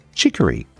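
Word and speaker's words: chicorée: Chicory. Chicory is spelt C-H-I-C-O-R-Y. And in French, it's pronounced chicory. But it's spelt C-H-I-C-O-R-E-E. But the first E has an accent above it.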